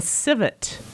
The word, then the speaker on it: civet: Is it a civet, the cat? – That's right, yes. – Okay, that's perfectly pronounced.